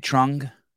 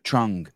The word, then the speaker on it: Trung: Trung? – Yes. – Trung.